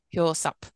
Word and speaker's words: Hyo-seop: So An Ho-seop Kills hope. – and Hyo-seop, thank you.